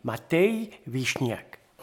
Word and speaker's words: Matei Vișniac: Si vous voulez la prononciation roumaine, c'est Matei Vișniac.